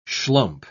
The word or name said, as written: schlump